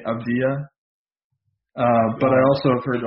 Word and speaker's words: Avdija: We got LaMelo Ball, who's been playing over in Australia, and Denny Avdija, I believe is the correct pronunciation. It's like a soft J.